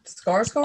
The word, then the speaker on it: Skarsgård: Skarsgård? – Oh, yes.